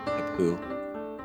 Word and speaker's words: Capoue: I know it's spelled C-A-P-O-U-E, kapoo.